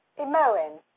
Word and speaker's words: Himoen: Sasquatch call themselves Imoen. – Imoen? – Yes. It's actually a silent H. So it is spelled H-I-M-O-E-N.